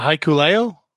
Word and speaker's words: Hikuleo: Chris Bey and can you help me with the pronunciation of Haku's son's name? – Uh, Hikuleo? – That's right.